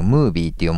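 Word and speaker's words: 夢ovie: 16 日都内で行われた北川さんの主演映画悪夢ちゃん、ザ・ムー夢ムービー、ムービーって読むんだね、漢字の夢に OVIE ですね、悪夢ちゃんザ・ムービーのイベントに北川景子さんと桃色クローバー Z が登場しました。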